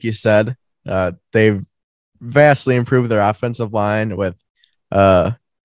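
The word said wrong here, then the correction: La'el: Collins. – Lyle, that's how you say it? – Yeah, Lyle Collins.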